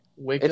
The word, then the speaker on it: Waco: It's not pronounced Waco. – No, it's Waco. – Okay, Waco.